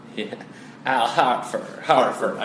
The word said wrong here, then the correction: Horford: I keep on saying Hartford. – Yeah, he's not a city in your beloved state of Connecticut.